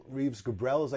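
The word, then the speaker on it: Reeves Gabrels: Rhys Gabriel, was that another one of his? – Reeves Gabriels, I guess is how you say his name.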